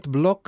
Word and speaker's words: Bloch: Το καινούργιο συγκλάκι των 33 Lovers που λέγεται Free, για τον Kurt Block που προφέρεται έτσι αλλά γράφεται B-L-O-C-H.